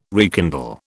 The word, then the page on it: rekindle: British English